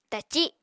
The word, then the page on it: taci: Apatani